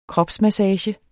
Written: kropsmassage

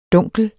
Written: dunkel

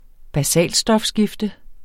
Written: basalstofskifte

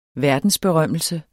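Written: verdensberømmelse

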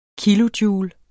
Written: KILOJOULE

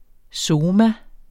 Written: soma

